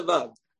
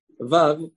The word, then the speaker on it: Vav: Now, he says, Rabotai, Vav, not, right, notwithstanding the common minhag of Rov of Am Yisrael, Sfaradim and Ashkenazim, pronouncing the letter Vav, with a V sound.